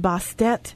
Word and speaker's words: Bastet: So what I've got here, the two goddesses that I pulled, one is Bast, also sometimes pronounced Bastet.